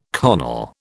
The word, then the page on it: carnal: British English